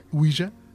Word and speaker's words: Ouija: ¿Cómo se pronuncia Ouija? – Ouija.